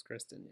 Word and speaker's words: Kristen: Is it Kirsten or Kristen Stewart? It's Kristen, huh? – It's Kristen, yeah.